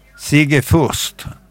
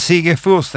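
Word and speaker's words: Sigge Fürst: Vi tar svaret en gång till. – Sigge Fur. – Sigge Furst. Furst. – Ja, men mitt uttal. – Jag uppfattar inte Furst riktigt. – Ja, men jag tar det snabbt. – Ja. Sigge Furst, han kom allra först. – Ja. – Men Sigge Furst är rätt.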